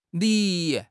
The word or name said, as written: li2